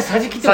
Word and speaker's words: 桟敷: あれでさじきって球場 3 色でさじきと読む